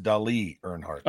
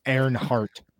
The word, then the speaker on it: Earnhardt: That's pronounced Dali Earnhardt. – Oh yeah, Dali. I forgot. – It's actually Earnhardt. Earnhardt.